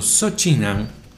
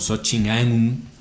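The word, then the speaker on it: Soh Chin Ann: Este momento, Sochin Aun, o Sochin Aun, como usted quiera pronunciarlo, porque dependiendo de dónde traduzcas del malayo su nombre, es un ANN o un AUN, Sochi, termina en el registro de Malasia con 219 partidos.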